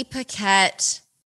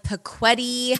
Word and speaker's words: Paquette: You see Paquette. I don't know. Is it that confusing? I don't know. But people really want to call like Paquetti. Paquetti. it gets butchered.